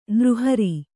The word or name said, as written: ನಿರಾಹಾರ